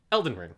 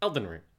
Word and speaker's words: Elden Ring: No, I mean, it's just been Elden Ring, as I want to try to respect the voice director's choice that it's a dactyl. It's stress, unstress, unstress. Every actor calls it Elden Ring. It's not Elden Ring. It's Elden Ring.